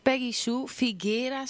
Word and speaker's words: Peggy Sue Figuera: Ten eerste Peggy Sue Figuera, zeg ik dat goed? – Ja.